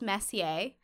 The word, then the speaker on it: Messier: Um 1758, French astronomer Charles Messier mistakenly That's identifies Huh?